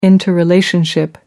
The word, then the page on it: interrelationship: American English